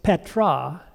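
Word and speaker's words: Petra (πέτρα): The word rock, pronounced Petra in Greek.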